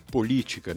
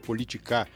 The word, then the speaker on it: política: Se referindo a um trecho em que não foi observada a acentuação tônica na pronúncia. Por exemplo, na palavra política, né? Que ficou politicar.